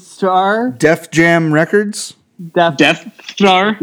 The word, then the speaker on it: Deth: His last name is D E T H. Death.